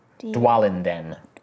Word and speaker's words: Dwalinden: D-W-A-L. – A-L. – I-N. – I-N. – D-E-N. Dwalinden.